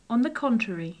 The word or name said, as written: on the contrary